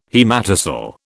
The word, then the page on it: haematocele: British English